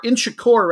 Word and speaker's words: Inchicore: Now, your visitors, St. Patrick's, they are out of, I believe it's pronounced Inchior or Inchicore, rather, Dublin.